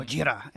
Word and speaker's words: Gojira: Gojira, as yep. it's pronounced in Japanese.